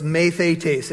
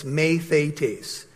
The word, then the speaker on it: mathetes: Note takers, the Greek word for disciple is mathetes. It's spelled math, like our word math, M-A-T-H, and E-T-E-S on the end. M-A-T-H-E-T-E-S, mathetes.